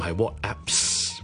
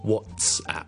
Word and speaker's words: WhatsApp: 那么第三个就是 WhatsApp, 不是 WhatApps, but WhatsApp, WhatsApp, WhatsApp, 扔埋的 WhatTS, 扔埋个 App 就变成 WhatsApp。